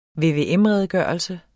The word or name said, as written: VVM-redegørelse